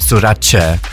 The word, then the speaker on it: Sorace: It's like Versace. Versace is pronounced Versace. Sorace.